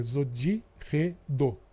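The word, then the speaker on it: ZIRDO: É, é, quando tem a letra Z, a pronúncia nós normalmente falamos Zod, Zod. Né? que é o que está no, nos diários. Então é Zodirredô.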